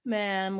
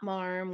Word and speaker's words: ma'am: That's, so if they're saying, oh, ma'am, you don't need to, ma'am with rhymes with ham, not marm with rhymes with farm.